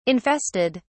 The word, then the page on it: infested: British English